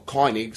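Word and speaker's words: König: Y su contraparte de la Alemania nazi es Ed Harris, como el Major Koenig, o Koenig, si lo querés pronunciar bien con la diéresis.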